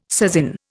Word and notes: sasin: Indian

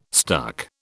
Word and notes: stuck: British English

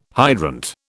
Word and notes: hydrant: British English

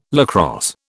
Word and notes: lacrosse: British English

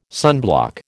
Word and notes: sunblock: American English

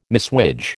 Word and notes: messuage: American English

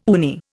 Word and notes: uni: Indian

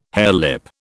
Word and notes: harelip: British English

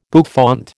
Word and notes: bouffant: American English

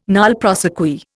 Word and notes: nolle prosequi: Indian

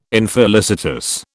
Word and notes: infelicitous: British English

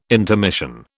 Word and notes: intermission: British English

